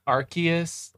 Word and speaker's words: Arceus: Yes, I say it Arceus. – Actually, it's pronounced Arceus.